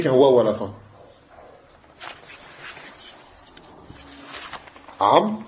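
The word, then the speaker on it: Amr (عمرو): Seulement, ce waou, on ne le prononce pas. Tu dis pas Amru.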